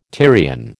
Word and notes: Tyrian: American English

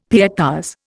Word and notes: pietas: Indian

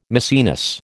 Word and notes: Maecenas: American English

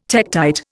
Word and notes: tektite: Indian

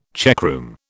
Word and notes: checkroom: British English

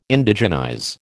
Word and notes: Indigenize: American English

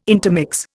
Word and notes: intermix: Indian

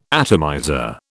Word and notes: atomizer: British English

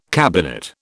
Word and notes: cabinet: British English

